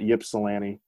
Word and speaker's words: Ypsilanti: And it's Ypsilanti, not Ypsilanti, mm-hmm. For people who pronounce it wrong.